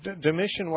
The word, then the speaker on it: Domitian: or is it Domitian, is that how you say his name? – Yeah, Domitian wasn't until later.